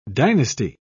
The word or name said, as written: dynasty